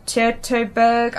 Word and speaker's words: Teutoburg: T-E-U-T-O-Burg. – Teutoburg. – Well, it's like, I don't know. – It's Deutsche. – Yeah, it's German. Teutoburg, I don't know.